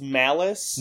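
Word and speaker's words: Malus: So, our one true god, Nicolas Cage, he plays a cop character named Edward Mullis. – I'm pretty sure it's Malice. – And I'm pretty sure it's supposed to rhyme with phallus.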